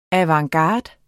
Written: avantgarde

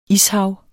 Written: ishav

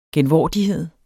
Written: genvordighed

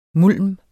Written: mulm

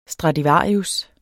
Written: stradivarius

